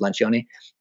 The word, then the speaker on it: Lencioni: It's about, you might pronounce it different if you speak it in like American or British English, but in Italian it's Lencioni.